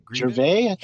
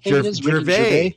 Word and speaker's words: Gervais: And that Ricky how do you say his name? – Gervais, Gervais. That's it.